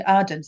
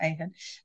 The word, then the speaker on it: Ardern: But I am in Ardern, sorry, not Ardern. Got her name right.